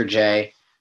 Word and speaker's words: Jay: Oscar J, spelled J-A-Y, Oscar, just like how you'd spell it the American way, O-S-C-A-R.